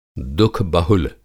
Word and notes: दुःख-बहुल: Hindi

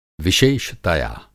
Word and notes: विशेषतया: Hindi

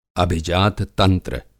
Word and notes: अभिजात-तंत्र: Hindi

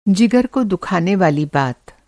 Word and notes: जिगर: جِگر

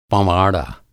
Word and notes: पँवाड़ा: Hindi

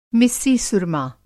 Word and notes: मिस्सी-सुरमा: مسی سرمۂ

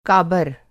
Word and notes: काबर: کابر

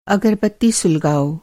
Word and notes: सुलगाना: سُلگانا

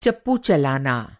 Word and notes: चप्पू: چپو

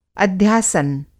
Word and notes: अध्यासन: Hindi

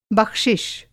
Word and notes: बख़्शिश: بخشش; Hindi

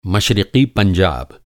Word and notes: मश्रिक़ी: مشرقی

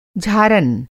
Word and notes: झारन: Hindi

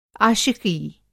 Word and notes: आशिक़ी: عاشقی

